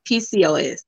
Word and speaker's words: PCOS: It's PCOS or POCS? – PCOS.